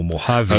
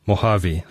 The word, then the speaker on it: Mojave: And I've got to look at how I wrote it phonetically because now it still makes no sense. Mojave. – I think that's right.